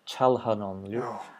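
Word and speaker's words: Çalhanoğlu: It is CH, so I should say Chalhanonlu.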